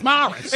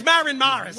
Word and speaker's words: Morris: that's not how you say it. It's Morris. – It's Maren Morris.